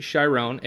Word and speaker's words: Chiron: When we first meet Sharon, is it Sharon for pronunciation? – It's either film, it's Chiron.